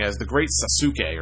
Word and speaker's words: Sasuke: And it is pronounced, at least the proper way in Japan, it is pronounced the great Sasuke.